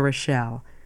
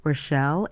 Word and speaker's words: Rochelle: For a copy of the schedule, just write to me, Rita Rochelle. My name is spelled R-I-T-A. Rochelle is R-O-C-H-E-L-L-E.